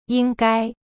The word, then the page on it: 应该: ying gai